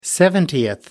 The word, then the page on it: seventieth: American English